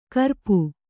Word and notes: ಕುರೂಪ: kurūpa

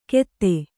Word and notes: ಗೆದೆ: kannada

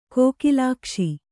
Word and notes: ಕೋಕಿಲಾಕ್ಷ: Kannada

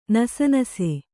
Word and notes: ನಸೆನಸೆ: nase nase

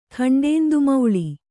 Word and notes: ಖಂಡೇಂದುಮೌಳಿ: Kannada

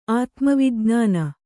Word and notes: ಆತ್ಮವಿಜ್ಞಾನ: kannada

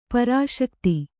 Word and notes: ಪ್ರಸಕ್ತಿ: Kannada

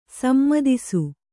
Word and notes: ಸಮ್ಮತಿಸು: sammatisu

